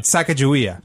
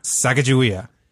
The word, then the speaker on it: Sacagawea: Our director could not pronounce Sacagawea. – Really? – So he'd be playing the piano and singing along as like, they hired to be their guide. we're like, it's Sacagawea.